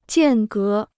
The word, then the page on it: 间隔: jiàn gé